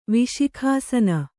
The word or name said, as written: ವಿಶಿಖಾಸನ